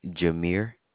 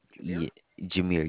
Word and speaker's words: Jameer: Jameer. That is spelled Jameire. – All right, Jameer. Almost like Jeremy, but it's Jameera. – Jameer, yes, sir, Jameer.